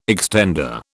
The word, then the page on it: extender: British English